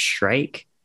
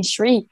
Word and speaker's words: shrike: I think it's pronounced Shrike. – Right. – Okay, because I've been saying Shriek all the time.